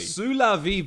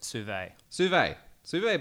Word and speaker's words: sous-vide: Sous-la-vie bags. Sous-la-vie, is that how you say it? – Sous-vie